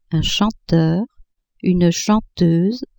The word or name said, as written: chanteur, chanteuse